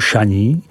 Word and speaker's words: Chagny: Potoblast, která je ohraničena vesničkou Chagny, vyslovuj Šaní, nabízí nějakých 25 kilometrů k obci Saint-Valérien, vyslovuj Saint-Verla, která tvoří hranici mezi Côtes-Chalonnaise a následující potoblastí Mâconnais, vyslovuj Mâconnais.